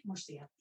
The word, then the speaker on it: Murcia: So this this is um, over in Spain in Murcia. Did, was did I say that right, Desiree? Murcia. Murcia. Um,